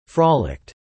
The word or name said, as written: frolicked